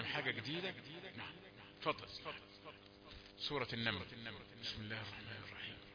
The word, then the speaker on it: بسم الله الرحمن الرحيم: كتير من القراء ما نسمعش منهم الباء دي. بسم الله الرحمن الرحيم. يبقى الباء ضعيفة لسه بيسخن. نقول له لا ما عندناش كلمة تسخين. من أول ما تنطق هات الحروف كلها سليمة.